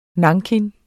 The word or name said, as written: NANKIN